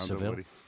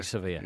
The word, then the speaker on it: Sevilla: Do they say Sevilla? – Sevilla, I think. – They do say Sevilla in the native tongue.